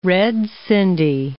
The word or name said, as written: red sindhi